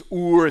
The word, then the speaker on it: Ur: We pronounce it Ur usually, but it's Ur.